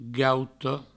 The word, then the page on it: gauta: gauto